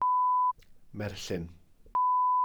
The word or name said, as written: merllyn